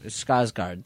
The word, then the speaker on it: Skarsgård: Skarsgård, thank you.